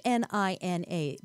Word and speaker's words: Hnina: Hnina is H N I N A, but pronounced Nina, the H is silent.